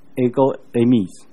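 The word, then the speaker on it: ego eimi (ἐγώ εἰμι): 在希腊文里面，我是这个字读作 ego eimi，有时候你会在英文里面看到这个字 ego eimi。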